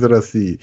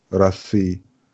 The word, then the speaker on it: России: Das O wird in den meisten Fällen wie so eine Mischung aus A und O ausgesprochen, oder? Wie so ein runterhängendes Kiefer. Mhm. A. So, Rassi, mhm. Maske, sowas, oder? – Genau. – Also es gibt ganz wenige Paruski, ganz wenige Wörter, in denen es tatsächlich wie ein O ausgesprochen wird.